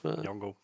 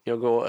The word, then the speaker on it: Nyong'o: Nyong'o. Nyong'o äh, in der Hauptrolle.